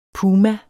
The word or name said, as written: puma